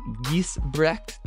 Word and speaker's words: Giesbrecht: We are here with Dana Giesbrecht.